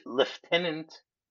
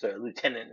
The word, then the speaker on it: lieutenant: Give the lieutenant my regards." I always like the fact that Brits mispronounce lieutenant and call it lieutenant, which Yeah, I don't know why we say that.